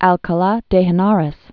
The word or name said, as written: Alcala de Henares